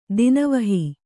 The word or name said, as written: ದಾನವಹಿ